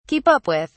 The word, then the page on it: keep up with: British English